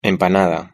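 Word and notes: empanada: Spanish (Spain)

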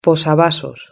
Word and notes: posavasos: Spain Spanish

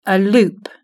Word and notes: a loop: British English

